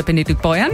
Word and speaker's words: Benediktbeuern: Hier bei mir sind Pater Heinz Menz, Direktor der Mitbrüdergemeinschaft der Salesianer Don Boscos im Kloster Benediktbeuern.